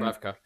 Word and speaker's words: Dubravka: Is it Dubravnik? – Dubrav, um, Dubravka. – Dubravka, that's it, yeah.